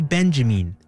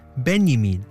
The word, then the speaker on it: Benjamin: Some say Benjamin, others Benjamin, or even simply Benjamin.